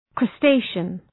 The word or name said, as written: crustacean